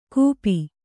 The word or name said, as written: ಕೂಪಿ